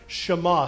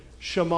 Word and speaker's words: Shema: How's it pronounced? Shema. Say it with me. Shema.